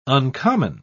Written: uncommon

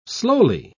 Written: slowly

